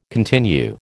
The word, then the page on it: continue: American English